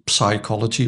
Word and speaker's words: psychology: Nou, als je dus psychologie in of psychology, dan hoor je het uitgesproken worden zonder P.